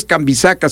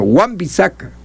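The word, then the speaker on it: Wan-Bissaka: No, este es Juan, W-A-N, guión, B grande, y ahí viene el apellido, que tampoco es tan sencillo en su, cuando menos en su escritura, sí en la pronunciación. Bisaca es B grande y doble S, A, K, A. Entonces, no es Cambisaca, sino Juan Bisaca.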